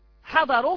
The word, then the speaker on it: حضروا: حضروا، حضروا فيه ألف في آخرها، مثلا سمعت حضروا فيه ألف في آخرها، هذه الألف لا تنطق، فهي يقال أنها زائدة لا تنطق، زائدة في التلفظ لا ترفض، لكن في الرسم غير زائدة، في الرسم غير زائدة.